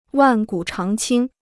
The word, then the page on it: 万古长青: wàn gǔ cháng qīng